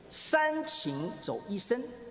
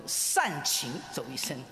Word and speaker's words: 三情走一生: 那今天早上和大家分享的题目是三情走一生啊，希望你不要读成善情走一生。